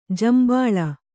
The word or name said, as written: ಜೇಂಬುಳು